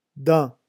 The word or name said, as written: dansa